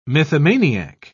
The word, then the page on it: mythomaniac: British English